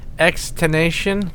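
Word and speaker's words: XXXTentacion: How do you say this guy's name? Is it Extension? – Extination. – Extination.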